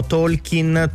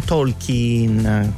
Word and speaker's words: Tolkien: E a proposito di errore, spesso in italiano noi sbagliamo la pronuncia dell'autore del Signore degli Anelli, diciamo Tolkien, Tolkien, invece si dice Tolkien con la I lunga.